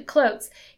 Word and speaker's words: Klotz: Um, he, oh, I think that you say his name actually, Clotes, he outlined four reasons that led to the great resignation.